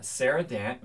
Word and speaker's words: Sara Dant: And we are joined with, as I just noted before this episode started recording, our most famous guest to date, Sara Dant with Weber State.